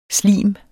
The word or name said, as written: slimet